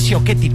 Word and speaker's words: Ciocchetti: Aparecen por allí en escena Ana Coschetti, Isabella Camil, Ana Ciocchetti, perdónenme, lo dije mal.